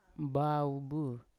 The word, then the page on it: mbaobu: Yawa